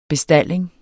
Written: bestalling

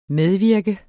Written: medvirke

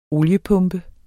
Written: oliepumpe

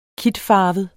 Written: kitfarvet

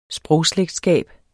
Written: sprogslægtskab

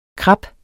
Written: krap

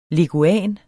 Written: Leguan

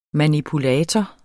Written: manipulator

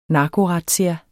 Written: narkorazzia

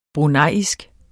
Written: bruneisk

